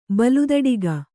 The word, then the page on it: ಬಲುದಡಿಗ: baludaḍiga